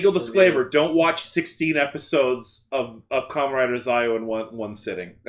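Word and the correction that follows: Zi-O: Legal disclaimer: it's Zio. Why do you keep mispronouncing things? – Because it bothers you. – Are you actually doing it on purpose? – No, that's just how I've always pronounced it. I didn't realize that was a thing. – I say Zio in the show! – What am I saying? – Zio! You're using the wrong version of the letter I.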